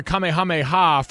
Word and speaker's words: Kamehameha: Kamehameha from Dragon Ball Z.